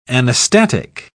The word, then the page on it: anastatic: British English